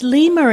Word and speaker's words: lima: Interestingly, 5 is pronounced Lima in the languages of many Austronesian peoples in and outside Taiwan.